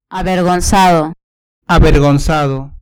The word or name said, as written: avergonzado